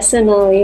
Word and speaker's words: Sonali: Can you pronounce it? – Sonali? Sonali? – Sonali, yeah. – Mm-hmm. Sonali? Did I say it right? – Yep.